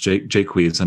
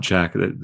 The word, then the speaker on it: Jaques: So Jaques, weirdly, I think his name is pronounced Jaques and not Jack. They Anglify it.